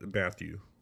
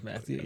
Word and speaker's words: Mathieu: Actually, I guess it could be pronounced Matthew. – Yeah, it is Matthew. Yeah. But I always want to pronounce it Mathieu when I look at it.